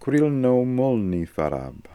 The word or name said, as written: Krilnomolnifarab